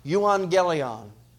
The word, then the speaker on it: Euangelion: Euangelion is the name of it, is how it's pronounced.